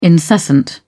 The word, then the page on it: incessant: British English